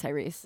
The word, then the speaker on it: Tyrese: Tyree, am I saying that right? – Where I think it's Tyrese.